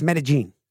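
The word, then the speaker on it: Medellín: It's not Medellin. It's Medellin.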